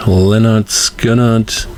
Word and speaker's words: Lynyrd Skynyrd: Das Album hieß damals Lynyrd Skynyrd, pronounced Lynyrd Skynyrd.